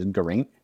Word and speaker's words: Geraint: I've been corrected on this by my viewers, so I had to mention it, but I think I've been saying it Geraint, and then the European way to say it is Geraint, and I figured out why.